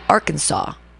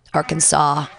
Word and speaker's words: Arkansas: Since the letter S at the end of a French word is usually silent, we pronounce Bill Clinton's home state, Arkansas. Oh, because the S on the end is silent, so you just end with the A. Arkansas, ah, the A sound.